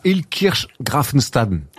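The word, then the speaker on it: Illkirch-Graffenstaden: Ouais, il joue à Ilkirch Grafenstaden. – Très bien prononcé, hein, parce que c'est pas toujours évident.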